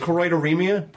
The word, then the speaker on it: choroideremia: Choroideremia? – Perfect.